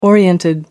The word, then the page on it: oriented: British English